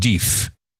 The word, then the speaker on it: d'If: Diff, he says, as you pronounce it, Diff. D'IF.